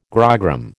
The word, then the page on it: grogram: American English